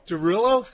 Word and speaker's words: Derulo: Derulo? – Derulo.